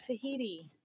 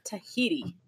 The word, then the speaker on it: Tahiti: Tahiti. – Tahini? Like the... – Tahiti. – Like Tahini? – The spread? – Tahiti.